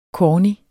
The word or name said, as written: corny